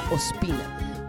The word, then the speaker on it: Ospina: en todas me llamo Pamela Ospina. Ospina se escribe O-S-P-I-N-A, así que búsquenme ya mismo.